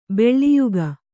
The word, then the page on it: ಬಲಿಯಿಕ್ಕು: baliyikku